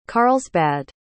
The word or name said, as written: carlsbad